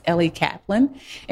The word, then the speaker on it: Ellie Kaplan: I said her name wrong. I said Ellen. There's no A in there anyway. It's Ellie Kaplan.